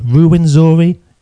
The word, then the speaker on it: Ruwenzori: Honestly, did I mispronounce that name, Ruwenzori? – No, you got it spot on for the English pronunciation.